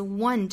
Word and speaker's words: one: 那我们来看第一个单词 one 这个词。这个词虽然是以元音字母打头的，但是要注意它其实发音来说是辅音作为第一个音的，它是 w 这个辅音，所以这个词应该是 w 再加后面的倒三角的元音啊哇哇，再加鼻音闭合 one，舌尖抵上齿龈，用鼻腔发声的 one one，不要口型咧得过大，发成了 one。